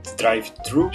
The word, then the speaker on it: drive-thru: Eu não consigo pronunciar direito, é drive-thru, é isso? Pronunciou certo.